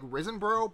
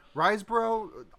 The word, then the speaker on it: Riseborough: I keep wanting to go at it like Risenborough, but there's no N. Riseborough, I'll just say that.